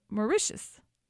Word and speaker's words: Mauritius: And I'm saying this wrong, and I practice it. I don't know why, but it's Mauritius.